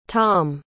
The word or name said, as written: thom